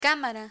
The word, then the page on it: cámara: Spanish